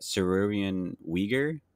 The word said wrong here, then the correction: Sigourney Weaver: Sigourney Weaver. – Sigourney Weaver, thank you.